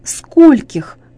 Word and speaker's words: скольких: Не забывайте также, что ударение в этих формах падает на букву «о» – «до скольких».